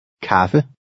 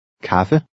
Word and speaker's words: Kaffe: Since the A is followed by two consonants, it makes a short A sound. Listen and repeat. – Kaffe. Kaffe.